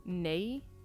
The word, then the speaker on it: neye: The subtitles, it's spelled N-E-N-A-T-E, and it means tree. And the other is ney. Again, according to the subtitles, it's spelled N-E-Y-E, and it means mother.